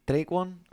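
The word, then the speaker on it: Tre'Quan: Te puedo decir que take, ah, Traquan, Traquan Smith, así, Traquan, así se pronuncia.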